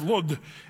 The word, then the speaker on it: Lod: Now, I just want to say, this is kind of fun here, because this place, Lydda, it's also in modern day, it's called Lod, L-O-D. And that's how they say it in Israel, Lod.